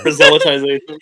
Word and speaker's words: proselytization: shut up alien. proselytization i said it correctly